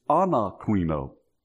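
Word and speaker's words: anakrino (ἀνακρίνω): It's anakrino.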